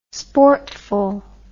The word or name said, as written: sportful